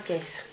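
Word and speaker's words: Thiès: De Chess, se llama Chess.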